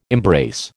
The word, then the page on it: embrace: American English